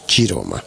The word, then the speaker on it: Chiroma: Y Chiroma se escribe con C, se escribe Chiroma, pero en italiano es Chiroma.